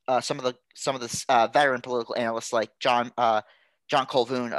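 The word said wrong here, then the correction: Couvillon: of Louisiana. – Cuvion, thank you. I still get those names wrong.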